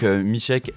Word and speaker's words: Misiek: Donc Michek, M-I-S-I-E-K, c'est ça? – Exactement, ouais. Ouais, j'ai droit à Misiek, Misiek, j'en ai plein, mais ouais, ça se prononce Michek.